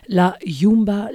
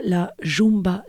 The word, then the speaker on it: La Yumba: Nous venons d'écouter Osvaldo Pugliese, La Yumba, La Jumba, La Chumba, 1946. Voilà, les trois versions de prononciation.